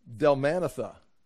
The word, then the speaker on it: Dalmanutha: How would you say it? Dalmunutha? Dalmanutha. Is that what you would say? – That's what I would say. – Okay. So you got the syllable Dalmanutha. Okay, Dalmanutha.